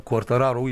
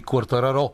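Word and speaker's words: Quartararo: и а, носителя на титлата от миналата година в MotoGP, Фабио Куартараро, или както биха го наричали французите, защото той се състезава за Франция, а французите, както знаеш, послагат ударения само където на тях им е угодно, независимо, че това има италианско, те ще биха го наричали Куартараро.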